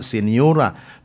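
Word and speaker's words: señora: Pero silent G man eh, muna senyora. Mausap sa Spanish, senyora.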